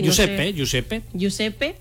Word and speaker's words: Giuseppe: Giuseppe. Es que no se comen letras en Italia, es como en español.